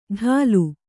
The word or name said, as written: ಡಾಲು